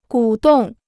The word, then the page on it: 鼓动: gǔ dòng